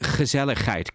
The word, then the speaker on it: gezelligheid: That's how you translate it, you know, and it's pronounced gezelligheid. Go on, say it.